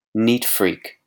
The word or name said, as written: neat freak